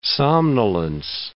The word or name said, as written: somnolence